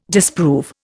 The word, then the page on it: disprove: Indian